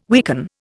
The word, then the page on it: weaken: Indian